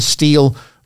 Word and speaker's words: Stihl: The Spring Steel sale, and steel is spelled STIHL.